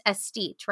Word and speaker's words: aesthete: and it's pronounced aesthete, right? – Yes, it is.